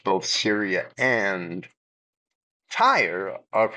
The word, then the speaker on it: Tyre: I will pronounce it Tyre, I hope, throughout the entire program. Sometimes I lean towards pronouncing it here, but that's because the Hebrew spelling is actually Taurus, Turos.